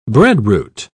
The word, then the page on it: breadroot: British English